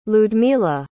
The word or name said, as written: lyudmyla